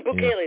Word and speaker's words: Bukele: I think it's Bukele. I don't know how to pronounce Bukele. – It. – Bukele, yeah. That's right.